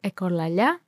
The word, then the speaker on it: echolalia: and if you want to know how it's pronounced in Greek, is echolalia,